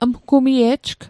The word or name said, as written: mgumie'jg